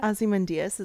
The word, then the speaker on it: Ozymandias: Ozymandias, is that how you say it? – It is, because again, classics major.